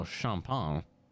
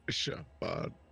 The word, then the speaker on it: champagne: Champagne. You mean it's not pronounced champagne? – Nah, champagne. I believe it's a fancy drink, champagne. You don't pronounce like 16 of the letters.